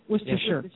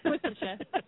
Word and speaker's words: Worcestershire: So Worcestershire really does have three syllables. – Yeah. – Worcestershire. Okay, not the six syllables that us Americans give it. – What's we tend to call it Worcestershire.